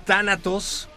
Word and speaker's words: Tánatos: Ellos son Tánatos, con acento en la A, por favor.